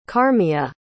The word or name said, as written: karmia